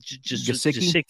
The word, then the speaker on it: Gesicki: Gesicki.